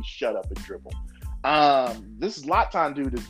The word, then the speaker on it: Zlatan: Zlatan, Zlatan, I know this is how to say his first name.